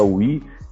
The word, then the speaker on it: Wii: Quer dizer, we, nós em inglês, se escreve we com W-I-I, né? Mas se fala we e remete a, a nós, a todos aí, de certa forma.